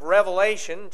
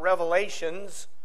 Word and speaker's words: Revelation: your Bibles with me to the book of Revelation, chapter 21. You know, sometimes people mispronounce this book. It's not Revelations. There's only one.